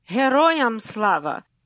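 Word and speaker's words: Heroyam Slava (Героям слава): And the answer is Heroyam Slava.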